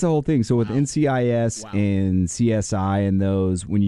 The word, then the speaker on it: NCIS: And then Nikiss, is that how it's pronounced? – NCIS.